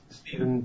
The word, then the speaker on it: Stephen: Yes, it's Stephen Pusey.